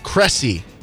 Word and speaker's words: Cressy: Maxime Cressy. – Oh, Cressy. – Must be Cressy. – So like Messi, but Cressy. – Yeah, yes. But it's not spelled like that at all.